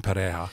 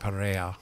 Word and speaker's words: Pereira: And major impacted cities like Cali, as Stephen just acknowledged, and Pereja, which is P-E-R-E-I-R-A, but Pereja. Sounds like a Y in my head.